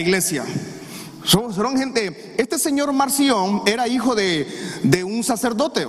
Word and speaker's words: Marción: Pero Policarpo tuvo un encuentro con un señor que se llamaba Marción, M-A-R-C-I-O-N, Marción, no se equivoque con otra palabra, ¿verdad? Marción, y una creo que lleva acento en la O.